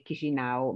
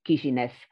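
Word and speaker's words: Chișinău: Allora, guarda, il nome moldavo è Chisinau, mentre per la popolazione di lingua russa è nota come Chisinev.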